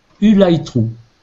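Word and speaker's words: Ulythrû: Ça s'écrit U-L-Y T H R U accent circonflexe. Alors U accent circonflexe, ça se prononce OU dans, le système de code. Et euh, le Y se prononce I, donc ça fait U light true.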